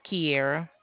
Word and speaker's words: Kiara: Yes, it's Kiera. – Kiera? How do you spell that? – K-I-A-R-A.